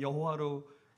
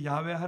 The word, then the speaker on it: 야훼: 그래서 우리가 여호와라고 번역했지만 사실 종합 발음은 야훼입니다. 그 야훼 하나님에 대해서 이 그들이 두렵고 떨리는 마음으로.